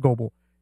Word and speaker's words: Goble: It's spelled G-O-B-L-E, but Gobel.